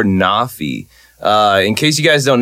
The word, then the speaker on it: NAAFI: So if you guys haven't checked out Nafi, it's spelled N-A-A-F-I, and it's just an abbreviation.